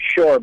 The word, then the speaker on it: Pschorr: You guys, it's spelled P-S-C-H-O-R-R, and in German, in English, you just pronounce it shore, but in German, you pronounce it shore.